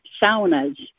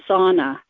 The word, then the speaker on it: sauna: And one thing that we grew up with was saunas. Americans pronounce them sauna, but it's sauna.